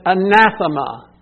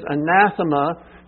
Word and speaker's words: anathema: The word accursed in the Greek is anathema. You may have heard that before. It's spelled A-N-A-T-H-E-M-A.